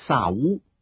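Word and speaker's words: 萨 乌 (שאול): 扫罗这个字的原文发音比较像是萨乌，所以这个小男孩的名字取自以色列第一个王，都属于同一支派，就取名为扫罗。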